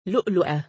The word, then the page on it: lu2lu2ah: female